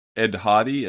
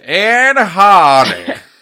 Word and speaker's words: Ed Hardy: I just want to point out, you said Ed Hardy. It's actually pronounced Ed Hardy.